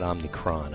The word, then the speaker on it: Omicron: I've been mispronouncing it. Omicron. It's not Omicron, I don't think. I think it's Omicron.